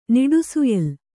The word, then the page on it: ನಿಡುಸುಯಿಲು: kannada